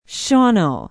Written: shawano

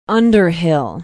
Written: underhill

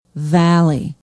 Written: valley

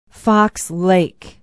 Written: fox lake

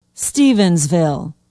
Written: stephensville

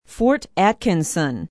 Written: fort atkinson

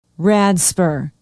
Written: radspur

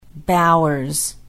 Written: bowers

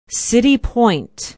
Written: city point

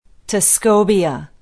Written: tuscobia

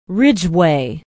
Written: Ridgeway

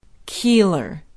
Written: kieler

